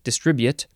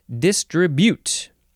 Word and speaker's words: distribute: D-I-S-T-R-I-B-U-T-E. You could also say distribute, if you like that, distribute. And the British say distribute. They emphasize the first syllable and then the last syllable less so. But here in America, where us Americans live, we like to emphasize only the second syllable, distribute.